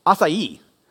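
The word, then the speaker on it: açaí: It's actually pronounced acai.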